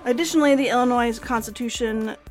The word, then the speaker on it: Illinois: When Congress admitted Illinois, oh my god, I always forget how to say it. Illinois. No S.